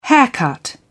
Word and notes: haircut: British English

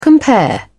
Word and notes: compare: British English

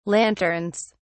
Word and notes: lanterns: British English

